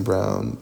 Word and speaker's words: Brown: Uh, my personal TikTok is Elisha Brown with two eyes in the middle of Elisha, last name Brown, like it's spelled in the color.